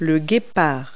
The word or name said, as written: cheetah